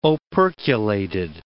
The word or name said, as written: operculated